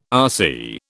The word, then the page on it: RC: British English